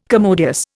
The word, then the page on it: commodious: Indian